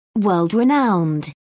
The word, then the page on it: world renowned: British English